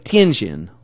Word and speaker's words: Tianjin: But you have to say like Tianjin, like that little inflection.